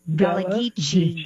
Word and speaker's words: Gullah Geechee: Gullah Geechee. – Gullah Geechee. Thank you.